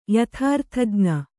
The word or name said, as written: ಯಥಾರ್ಥಜ್ಞ